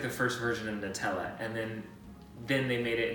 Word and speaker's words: Nutella: On the company's website, they say that it is pronounced Nutella, which to me drives me nuts, because if it's hazelnuts, it should be like Nutella. But they say it's Nutella.